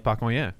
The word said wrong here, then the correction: Pacoima: The great city of Pacoima.